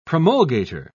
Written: promulgator

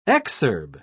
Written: exurb

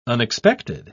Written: unexpected

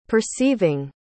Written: perceiving

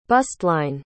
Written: bustline

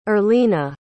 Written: Erlina

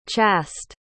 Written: chast

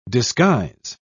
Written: disguise

